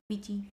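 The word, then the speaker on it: Quechee: And then we went to, how do you say that? Gorge place. Queechee. – Queechee.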